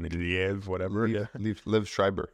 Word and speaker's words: Liev Schreiber: which in the movies has been played by different people, from Willem Dafoe to Liev Schreiber, and I hope I'm saying that, Liev Schreiber. – Yeah, sure. – I've heard him say his name.